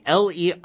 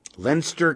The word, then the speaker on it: Leinster: But apparently, Leinster, the German in me says this would be Leinster, right? But no, it's actually British. Leinster County, according to Billy Stallings, and Will Jenkins, who took the name, pronounced it Leinster. Never knew. Never knew the guy's pseudonym was Murray Leinster, not Murray Leinster.